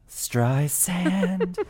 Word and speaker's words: Streisand: Plus, we've got oodles of chart chat oodles of chart chat about Barbra Streisand, soft S, don't forget, who earns her 11th number one album on the Billboard 200 this week.